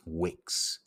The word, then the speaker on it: vix: Aggressively violent or harmful, coming from the Latin vix, actually pronounced wix, spelt V-I-X, meaning force.